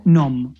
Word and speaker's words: Nome: Eh, caso este en teoría verídico, 2006 Alaska un pueblo llamado Nom, se escribe Nome, por si alguien lo quiere buscar.